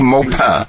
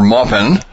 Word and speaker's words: Maupin: Today we're talking with Jeffrey Maupin, or Maupin. Depends on if you want the French or the English pronunciation of it.